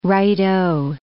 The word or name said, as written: righto